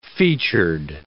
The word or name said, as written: featured